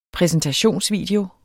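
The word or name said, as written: præsentationsvideo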